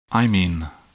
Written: imine

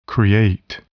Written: create

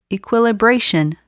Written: equilibration

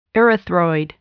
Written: erythroid

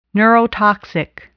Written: neurotoxic

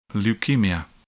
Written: leukemia

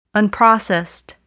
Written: unprocessed

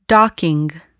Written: docking

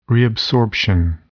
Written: reabsorption

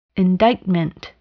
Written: indictment

